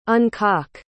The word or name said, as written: uncock